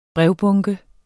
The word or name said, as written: brevbunke